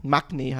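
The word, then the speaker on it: maknae: Ähm, Magne heißt das ja eigentlich mal und spricht ja das.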